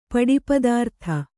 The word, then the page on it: ಪಡಿಪದಾರ್ಥ: Kannada